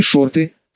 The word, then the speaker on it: шорты: Та же схема pump and dump чего стоит, а есть ведь еще и шорты, с ударением на «и».